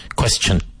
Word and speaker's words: question: So the final sound is like C-H-U-N. Chun. Question. I have a question. Do you like asking questions? Yes, I like asking questions. So it is, even though it's a T, it's pronounced like a C-H.